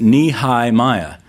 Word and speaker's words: Nehemiah: I'll never forget Nehemiah because it was like you were to pronounce it Nehi-Miah, and you saw this giant Nehemiah building down to build a wall that was as tall as his knee.